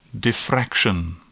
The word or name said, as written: diffraction